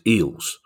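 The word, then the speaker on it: Eales: And in brackets, it says pronounced Eels. Now, I don't know if that's come from Eric Eels himself or from producer Guy. I know I struggle with names, but it's spelled E-A-L-E-S. I think I would have gone straight for Eels.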